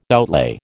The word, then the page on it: outlay: American English